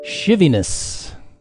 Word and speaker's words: shivviness: And the word that Listener Sunny has kindly provided us is shiviness. Shiviness. And that is spelled S-H-I-V-V-I-N-E-S-S.